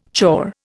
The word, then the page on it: chore: Indian